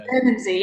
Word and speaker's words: Bermondsey: Bermondsey, yeah. Nice pronunciation, yeah.